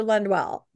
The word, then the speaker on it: Lundwall: Dr. Lundwall's brother contacted me and told me that I am slightly mispronouncing their last name, which is Wall. Now, I know that. I'm from Washington State. I tend to say things that have the all sound with an So I'm sorry, Dan Lundwall.